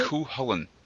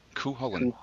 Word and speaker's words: Cúchulainn: What is it? – Cúchulainn. Cúchulainn. – Cúchulainn. Yes.